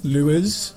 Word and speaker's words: Lewis: It's not a soft S at the end, though. Lewis.